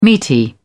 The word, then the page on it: meaty: British English